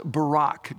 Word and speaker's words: barak: It's pronounced Barak, just like our former president.